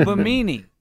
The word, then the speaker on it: Bimini: Benemy. – No. – Oh, Bimini. – Is that how you say it? Bimini? – It's like bikini, from what I could find here.